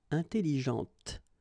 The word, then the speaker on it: intelligente: Okay, intelligente. Same thing here. When you put this well, actually, you will pronounce the final T, okay, but you don't pronounce this E here, intelligente.